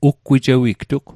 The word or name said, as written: uggwijewigtug